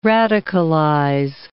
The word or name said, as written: radicalize